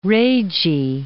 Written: Ragae

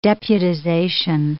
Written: deputization

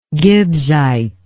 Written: gibbsite

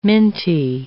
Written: minty

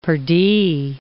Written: pardie

